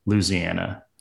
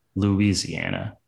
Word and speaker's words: Louisiana: People who live around there, or live there, I think they say Louisiana. But then most of us maybe outside say Louisiana, kind of that extra syllable.